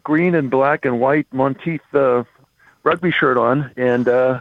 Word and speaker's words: Monteith: How do you pronounce it? is it Monteith? – Yeah, Monteith. Yeah, that's nice.